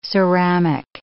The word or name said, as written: ceramic